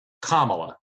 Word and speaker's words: Kamala: It's not, it's Kamala.